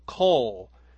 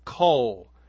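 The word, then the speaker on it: coal (קול): And this word we could pronounce something like coal, like a Q-O-L, coal.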